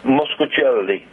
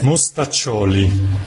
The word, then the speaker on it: Mostaccioli: Mostaccioli.